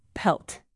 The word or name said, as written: pelt